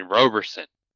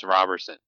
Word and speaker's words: Roberson: Because I said Roberson, and I'm not going to say who, but this person said, oh, you mean Roberson. And I was like, oh, okay. So then on the podcast, I made sure we had the correct pronunciation, which I now know. Is wrong because Nathaniel Green, book club member, talked to the guy himself and got the actual pronunciation, which is just what we thought originally, Chris Roberson.